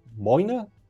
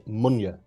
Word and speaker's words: Moynagh: If you read the name in a book, M-O-Y-N-A-G-H, you'd of course say Moynagh.